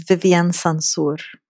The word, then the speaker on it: Vivien Sansour: My name is Vivien Sansour.